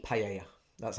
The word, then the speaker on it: paella: Paella. That's how you pronounce paella.